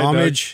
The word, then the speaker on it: homage: Homage. – Doug? – You pay homage. I think homage is the French pronunciation of it.